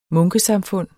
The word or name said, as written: munkesamfund